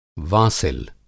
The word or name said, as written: वासिल